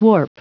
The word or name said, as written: warp